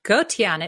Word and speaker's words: Gotian: Did I get the pronunciation of your last name correct on that intro? – Gotian. It means good person.